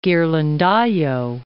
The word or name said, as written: Ghirlandaio